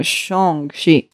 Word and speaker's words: Shang-Chi: Shang, Shang, Shang, Shang, Shang Según Simulio lo tendríamos que pronunciar como S-H-U-N-G. Shang, Shang, Shang shang pero o sea, no como Shung, sino como Shang-Chi.